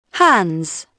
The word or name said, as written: hands